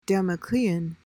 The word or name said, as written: damoclean